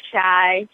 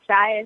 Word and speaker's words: Shai: So my YouTube is simply Shai. Shai is spelled S-H-A-I. You can follow me on Instagram at Shai Simply, S-H-A-I Simply.